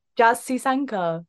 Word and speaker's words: Jazz Sisanka: And, and I'm Jazz Sisanka. – It's wonderful. It sounded really um, sounded really genuine and nice and off the cuff. That's how I say my name, Jazz Sisanka.